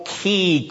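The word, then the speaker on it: key (כי): In the Hebrew text, as you will notice from your handout, there is a double key clause in this second question of David. That character, which is actually two letters in Hebrew, is pronounced key.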